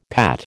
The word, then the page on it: Pat: American English